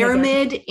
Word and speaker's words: Airmid: Aramid Institute.